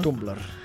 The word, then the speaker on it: Tumblr: Tumblr.